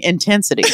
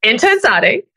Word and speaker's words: intenSati: I think you're mispronouncing intensity. – Okay, can read. – It is intenSati, which is you just don't do working out random, no thoughts behind your moves of your workout.